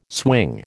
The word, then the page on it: swing: American English